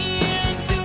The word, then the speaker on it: into: I'd hate to look into those eyes. – I hate to. Hate. I hate to. Look into. Vamos a vincular más into. Acentuando la primera sílaba.